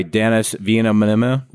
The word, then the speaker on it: Denis Villeneuve: This was directed by Denis Villeneuve.